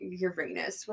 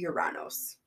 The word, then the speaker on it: Uranus: So, to combat that, the teacher that I had, she's like, we're not calling it Uranus, we're calling it Uranos. – Uranos is how the Romans probably pronounced that.